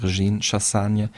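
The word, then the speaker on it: Régine Chassagne: Äh, zusammen er, Win und seine äh, Bandpartnerin, äh, die andere Bandchefin, kann man sagen, und Ehefrau auch, Regine Chassagne, ähm, haben Schön ausgesprochen hast. du. – Danke, ich kann ja auch Französisch.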